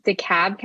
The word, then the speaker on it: DeKalb: Is it, do you pronounce it DeKalb County? – Mm-hmm.